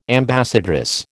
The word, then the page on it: ambassadress: American English